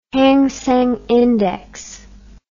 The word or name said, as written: Hang Seng index